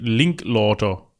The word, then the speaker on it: Linklater: Link, wie spricht man den? Linklauter.